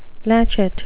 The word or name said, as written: latchet